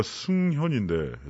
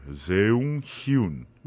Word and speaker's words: 승현: 제가 승현인데 세웅 히운 음.